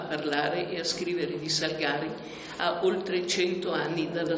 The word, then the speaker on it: Salgari: Allora, la pronuncia giusta è Salgari, perché essendo veneto, la famiglia originaria di Negrar Valpolicella, la pronuncia è, cioè, è Salgari e deriva dal vocabolo salice, che in veneto si chiama Salgari. Quindi non c'è dubbio che non è Salgari, ma è Salgari.